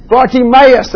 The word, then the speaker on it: Bartimaeus: The man who was blind, he's often referred to as blind, Bartimaeus.